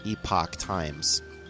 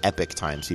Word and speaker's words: Epoch Times: Epoch Times, not epic times, people always say it wrong,